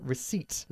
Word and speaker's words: receipt: Ojo con la pronunciación de la palabra receipt. La P es totalmente muda, okay.